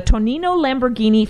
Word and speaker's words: Tonino Lamborghini: It's the, and I hope I'm saying it right, Sean, the Tonino Lamborghini flagship store.